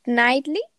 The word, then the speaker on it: Knightley: Y sale esta Keira, ¿cómo se dice su apellido? Keira Kingley, algo así, ¿no? – Knightley. Eh, Knightley.